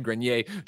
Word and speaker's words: Grenier: If it's French, then it's Grenier. If it's not French, I would say Grenier. – Okay. But I can't tell. Says Greg said it right.